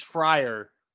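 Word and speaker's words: Fraire: Eddie Ferreira, not Ferreira, it's F-R-A-I-R-E versus Josh Walker. So Eddie is not one of the Pitbull brothers, sadly. He is Frayer, or it's a Fryer. Fryer? Do you know how to say his name? – I think it's Fryer. – Fryer, and then he should spell it differently. Oh, these people. – I mean, it's Fryer with an E.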